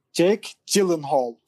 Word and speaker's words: Gyllenhaal: Jake Gyllenhaal.